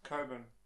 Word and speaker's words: Cockburn: Is that actually how you pronounce it, though? – Yeah, Cockburn. – Cockburn or Coburn? – No, Cockburn. – It's Coburn. – Apparently, I've been corrected by our station hand. It's apparently Coburn.